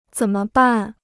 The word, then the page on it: 怎么办: zěn me bàn